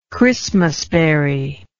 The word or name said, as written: Christmas berry